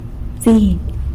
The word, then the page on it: bziny: sanlucasquiavini